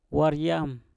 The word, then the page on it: war yam: mpur